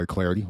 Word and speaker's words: Clardy: Follow me on Twitter at Troy Clardy. Last name is spelled C-L-A-R-D-Y.